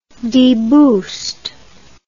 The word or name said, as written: deboost